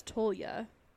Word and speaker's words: Tolya: Um, did I just say her name right? I don't know. Toya. – Tol I thought it was Tolya. – Because T O L.